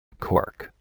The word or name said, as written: cork